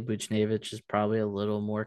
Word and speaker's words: Buchnevich: For Pavel Buchnevich, is that how you say his name is? – Yeah. – Buchnevich.